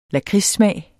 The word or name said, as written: lakridssmag